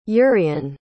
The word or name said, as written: urien